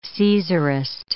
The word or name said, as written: Caesarist